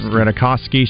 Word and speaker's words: Ratajkowski: Ratajkowski.